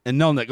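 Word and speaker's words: Anirniq: That's Councillor Sarah Hamilton representing the Edmonton Electoral Ward, CP Winniwak, Councillor Aaron Rutherford from Inarnik. Am I doing okay in pronouncing your ward, by the way, Councillor Rutherford? – It's Inarnik. Okay, thank you so much.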